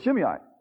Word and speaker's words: Shimei: You pronounce that better than I do. That's good, Shimei.